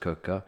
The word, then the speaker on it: Quooker: It's obviously spelt Quooker, but pronounced Cooker.